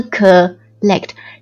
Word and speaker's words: collect: 其实啊，刚刚讲的这几种情况我们都可以用一个单词来表达，那就是 collect collect，注意读音，千万不要把这个呃音去掉，它是 collect，千万不要读成 collect，不要把它弱掉，弱掉的话就不知道是什么了。来 collect collect C-O-L-L-E-C-T collect，它的基本意思大家应该都挺熟悉的，它是表示采集或者收集，比如说收集资料，收集数据， to collect data，收集证据 to collect evidence，收集信息 to collect information。